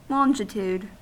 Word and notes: longitude: American English

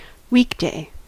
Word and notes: weekday: American English

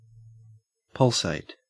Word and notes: pulsate: Australian English

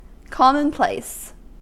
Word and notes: commonplace: American English

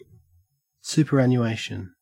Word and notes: superannuation: Australian English

